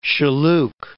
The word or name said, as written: Shilluk